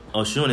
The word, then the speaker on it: Oshun: Oshun. – Oshun.